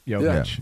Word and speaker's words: Jokic: You know, did I say it right? – Yeah. Jokic.